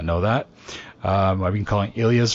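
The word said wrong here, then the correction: Illyes: forever, so it's Ish.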